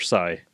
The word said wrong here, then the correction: Sia: I've always heard of Sia. – Sia, okay. Sia. I was pronouncing it wrong. Okay, Sia. – Yeah. – How's it spelled? Sia Coin. – S-I-A.